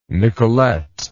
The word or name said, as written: nicolette